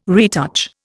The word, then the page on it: retouch: Indian